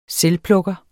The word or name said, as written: selvplukker